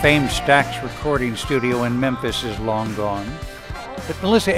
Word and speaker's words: Memphis: Memphis. It's got the capital M-E just for my initials, but it's Memphis.